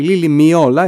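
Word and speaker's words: Lily Meola: Η Λίλη με όλα, έτσι προφέρεται θαρό.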